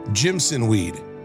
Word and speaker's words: Jimson weed: It'll look like a Jameson weed. Am I saying that right? Jimson weed.